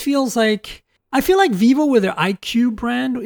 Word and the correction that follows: iQOO: Is that how you say it? IQOO, actually. – You spell it out.